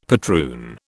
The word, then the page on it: patroon: British English